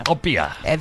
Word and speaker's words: Txopela: Copia.